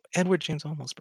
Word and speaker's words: Edward James Olmos: james edward Olmos or uh, however i pronounce his name james a, James Edward oh, Edward James Olmos bro.